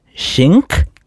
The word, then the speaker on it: Schenck: S-C-H-E-N-C-K. – Schenek, I think, yeah. – But there's no N at the beginning. Schenk? – Sure. – Would be Schenk? – Sally Schenk?